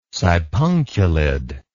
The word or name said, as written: sipunculid